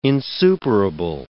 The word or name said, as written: insuperable